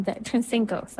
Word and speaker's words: Sinstenkos: There's an episode, I think more than one episode I did with Dr. Tracy Sinstenkos, I think I'm saying the last name wrong. It's been a long time. Sinstenkos, that's it.